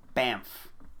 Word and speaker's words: Banff: Definitely, I assumed based on how it's spelled Banff or Bannf. Neither of those is particularly... – I believe it's Banff. Yeah. I assume that it would be pronounced Banff, but it's Banff.